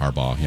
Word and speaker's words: Harbaugh: Harbaugh. Is that how you say it? My fault. – Harbaugh.